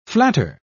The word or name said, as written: flatter